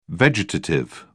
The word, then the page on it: vegetative: British English